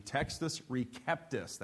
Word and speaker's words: Textus Receptus: became known as the Textus Receptus. That's the Latin pronunciation.